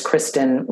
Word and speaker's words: Kristin: So it's Kristin Rowell, R-O-W-E-L-L. And Kristin is spelled K-R-I-S-T-I-N.